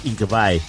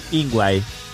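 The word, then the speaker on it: Yngwie: Si lo vas a pronunciar en su sueco original es Ingvae. – Sí. – Los norteamericanos le dicen Ingvae.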